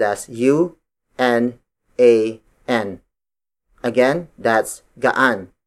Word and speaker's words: gaan: Gaan. Gaan. Gaan is spelled as G-A-A-N.